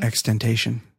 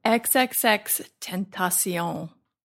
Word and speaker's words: XXXTentacion: Extentation. – No. – Extension. – No. Can I just do it? – It's XXX. You have to say all three X's.